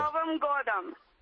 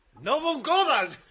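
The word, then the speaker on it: Novom godom (Новым годом): Novom godom. – ¡Novom godom!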